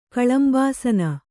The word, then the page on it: ಕಲಂಬಾಸನ: kalambāsana